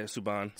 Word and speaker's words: Subban: Yeah, Subban.